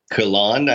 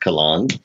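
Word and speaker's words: Colón: I've heard it pronounced Colon. – Well, that was by me just now. – Yeah. Well, yeah, but he's born Puerto Rico, so it'd be Colon. – Oh, Colon. Yeah.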